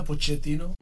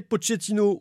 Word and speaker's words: Pochettino: Eh bien, il y a deux façons. – Como italiano que soy, Pochettino. Pero en Argentina, Pochettino. – Voilà, c'est comme vous le sentez, Pochettino ou Pochettino.